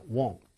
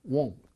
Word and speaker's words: won't: will은 자 won't 이렇게 발음합니다. 그리고 발음할 적에 이 want하고는 또 어떻게 달라요? 이런 거 물어보는 학생들도 있어요. 그런데 이 want는요, 그냥 우리가 want 이렇게 발음하고요. 요거는 요 콧소리가 좀더 나는 것 같아요. won't 정도에 좀 가깝습니다. want, won't 정도로 기억하시면 되겠어요.